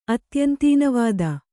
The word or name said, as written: ಅತ್ಯಂತೀನವಾದ